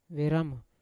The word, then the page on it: veramo: Yawa